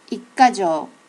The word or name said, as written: ikkajo